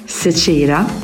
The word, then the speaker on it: Zecira: But how do you pronounce it in Swedish? – Zecira. Because we can't say Z in Sweden, so we use Zecira.